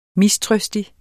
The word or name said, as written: mistrøstig